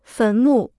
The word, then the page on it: 坟墓: fén mù